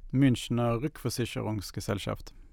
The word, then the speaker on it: Münchener Rückversicherungsgesellschaft: Münchener Rückversicherungsgesellschaft.